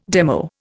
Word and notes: demo: Indian

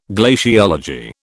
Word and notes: glaciology: British English

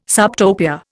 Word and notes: subtopia: Indian

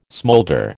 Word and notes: smolder: American English

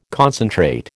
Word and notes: concentrate: American English

